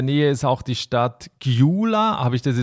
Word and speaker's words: Gyula: Ja, fast. Also das Ungarische hat ja mehr Buchstaben als wir im Alphabet. Das G und das Y ist dort ein Buchstabe, das ist Gyula. Also Gyula. – Gyula, ja.